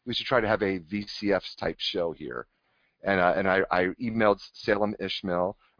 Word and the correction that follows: Ismail: or Ismail, I always get the name wrong, because I asked him, that's how you say it, Salem Ismail.